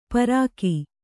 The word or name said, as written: ಪಾರಕ